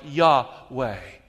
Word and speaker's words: Yahweh: The closest approximation we have is something like Yahweh. Say it with me, Yahweh.